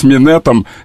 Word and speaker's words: минет: И да, но свитер с минетом, вообще-то, свитер... – А секс, как? – Свитер, секс. Свитер, но минет. Понимаешь, что тут мягкое, твердое нужно чередовать.